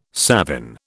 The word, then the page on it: savin: British English